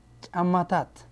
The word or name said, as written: shoes